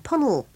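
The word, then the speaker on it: Puncknowle: It's from Derek Crosby, who lives in Punnell in Dorchester. Now, I feel very proud at being able to pronounce Punnell, because it's spelt Punk Noel, but I happen to know it's Punnell, because Derek told me once in a letter.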